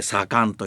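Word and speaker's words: さかん: それから 2 番目が次官、次官、次のが今だってこれ言うよね、長官次官は今だって生きてる、これ助と呼んで、それから判官、九郎判官、義経の判官、これ上と呼んで、それから主典、主な辞典の典、主典と書いて左官と呼んで、で左っていうのは発作の左ね、左っていうのは左手、右利きが多いから左手は右利きの助けだから、だから発作の左、左という字は助けるって意味さ。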